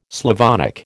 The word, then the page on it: Slavonic: American English